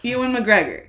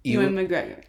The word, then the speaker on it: Ewan McGregor: Ewan McGregor. – Ewan? I can't. I was going to ask you how to say it because, yeah, I think it is Ewan. – Ewan McGregor.